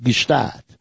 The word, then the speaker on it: Gstaad: It's pronounced Gstaad. and that's because it's spelled that way. G-S-T-A-A-D-T, I think.